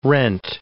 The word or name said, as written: rent